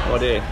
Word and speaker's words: Wadeye: How do you spell that? – W-A-D-E-Y-E. – Wadair, okay.